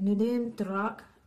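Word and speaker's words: Nûdem Durak: Oh, yeah, her name, I think it's pronounced how it is spelled. So it's like Nudem Durak, I think is how you say it.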